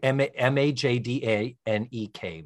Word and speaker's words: Majdanek: Yeah, and it's called that because uh, it's it was located in Majdan. So Majdanek is like Little Majdan, and that's outside the city of Lublin in Poland.